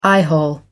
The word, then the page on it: eyehole: American English